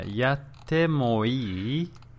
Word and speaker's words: yatte mo ii: So just listen to the pronunciation again. – Mm-hmm. – Yatte mo ii. – Yatte mo ii. – Yeah. It's got to have that inquisitive tone.